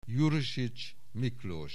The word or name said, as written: Jurisics Miklós